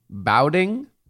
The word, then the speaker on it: Baoding: They're called How would you pronounce Baoding? Bowding? – B-A-O. Yes.